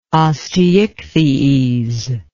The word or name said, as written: osteichthyes